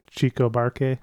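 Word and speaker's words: Chico Buarque: Did I say his name correctly? Chico Barque? – That's the way.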